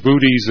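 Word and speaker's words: Boötes: But Booties is spelled B-O-O-T-E-S. That's the constellation Booties.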